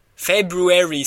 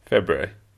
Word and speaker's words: February: I don't do any R in the February.